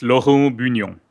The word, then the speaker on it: Laurent Bougnon: Well, so if I say the French way, right, it's Laurent Bougnon.